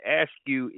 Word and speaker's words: askew: Askew, A-S-K-E-W. And askew is an adverb, and it also can be used as an adjective. And I said that wrong. I said askew, but it's actually pronounced askew. Askew. Okay? Askew. All right. So A-S-K-E-W.